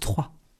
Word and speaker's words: Troyes: La ville se prononce Troyes.